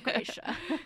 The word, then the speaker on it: Gracia: No one's going to say Gracia, even if it's like the technical Spanish pronunciation, which I am Puerto Rican, but I don't speak Spanish. So please, like, do not come for me if I said that wrong. It's my middle name and I say Gracia.